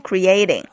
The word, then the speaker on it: creating: 注意一下这个 creating 这个单词，它的这个读音，重音呢是在中间的这个 a 上面 creating。